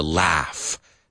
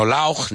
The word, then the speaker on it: laugh: They say it wrong. So he's always laughing. You have to laugh sometimes. Laugh. Okay? No lauch, ni lausch, ni nada de eso.